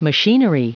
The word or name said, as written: machinery